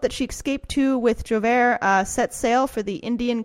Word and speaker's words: Joubert: Hervé Joubert. – Thank you. Joubert. That is way more French than what I said.